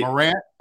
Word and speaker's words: Morant: And the number two pick behind Zion, if you recall, was Ja Morant. Durant, wait. – Morant, okay.